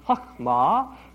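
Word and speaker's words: chokhmah (חכמה): Robert Gordas, in his book on Job, points out that the basic meaning of the word for wisdom in Hebrew, and the Hebrew word is pronounced chokhmah, means to have a skill.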